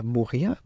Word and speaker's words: Mo'orea: Deswegen haben wir gemerkt, gelernt, dass äh, Moria, was mit zwei O's geschrieben wird, Mo, O, Re, R, E, A. Jeder Buchstabe wird ausgesprochen, weil den halt, ja.